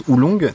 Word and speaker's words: oolong: Alors, tu me dis si je me plante, donc on prononce oolong, c'est ça? – C'est ça, tout à fait.